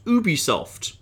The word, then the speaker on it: Ubisoft: Ubisoft have actually confirmed within that game that they prefer their company to be pronounced Ubisoft. Because yes. If you break into the Ubisoft offices, then the characters actually call it Ubisoft.